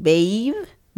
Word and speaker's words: Baev: It's A-E-V. – Okay, so Andre I got right, but then B-A-E-V is the last name. Baev? Baev? – Yeah. – B-A-V-E? – B-A-E-V. – Oh, Baev. – Baev.